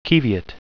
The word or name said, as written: qiviut